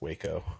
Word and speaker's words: Waco: She has directed Ally McBeal, The Practice, White Collar, Party of Five, Hell on Wheels, Sports Night, Burn Notice, Grounded for Life, Waco, or is it pronounced Wacko? It's Waco. – Yeah, I think it's Waco.